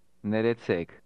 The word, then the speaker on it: Ներեցեք: Neretsek. Neretsek. – How do you say excuse me in Armenian? – Neretsek. Neretsek. – Did you hear the rolling R? This is common in Armenian.